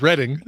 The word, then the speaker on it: Reading: It is Reading. It's in Reading, Pennsylvania. Whatever. You know what? You pronounce it. It when Duck your season. – House, in your house, your rules, you pronounce it however you want.